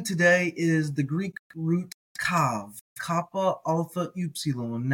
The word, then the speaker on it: καυ: Now, you may be, if you learned Erasmian, you may be learned to pronounce this as kau, but it's kav with the pointed Arab pronunciation, kappa, alpha, upsilon. That vowel pair, alpha, upsilon, is av, not al.